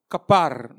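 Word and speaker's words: kapar: Existe uma palavra hebraica para essa, para isso aí, para propiciação, que é K de que bom, A de amor, P de pato, A de amor, R de rato. Capar, né, que se chama a pronúncia,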